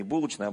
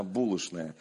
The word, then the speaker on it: булочная: Вы знаете, так вот в Москве такое вот произношение, не булочная, а булочная.